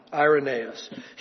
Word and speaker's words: Irenaeus: Then Irenaeus, Tony and I were, I was asking Tony Gibson, how do you pronounce that? Is it Irenaeus or Irenaeus? And I think we settled on the long I, Irenaeus.